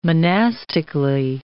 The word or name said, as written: monastically